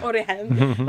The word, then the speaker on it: Origen: And please correct me if I'm mispronouncing names, but like Origen. Origen. Okay. I tried to make it, I think, more sophisticated. Like, I don't know Origen. – What I thought. Yeah, Origen. Like he becomes like a character because he weaves throughout in mm-hmm.